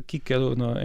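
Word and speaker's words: Cicero: Não, era Kícaro em latim. – Kícaro, no latim, sim, é sim.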